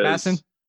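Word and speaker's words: Mattison: But the reason I lean Madison is Madison. Say it with two syllables. Do it like I do.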